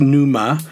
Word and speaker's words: pneuma: In Greek, it would be pneuma, and that's with a P and an N, pronounced with the P silent, so it's pneuma.